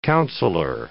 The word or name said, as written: counselor